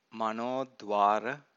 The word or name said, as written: manodvara